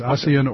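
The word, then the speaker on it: Ossian Sweet: Well, you know, I've heard seen it both ways, Ossian or Ocean.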